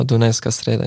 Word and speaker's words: Dunajská Streda: zu Dunajská Streda in der Slowakei. – Genau, Dunajská Streda in Slowakei oder FC Dac ist Abkürzung so.